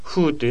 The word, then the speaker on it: 후: テグヌウェ、テグンというのが退勤で、フーというのが後という漢字、そのままフーと読むわけですけど、退勤後にということですね。